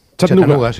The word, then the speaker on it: Chattanooga: Chattanooga, sí.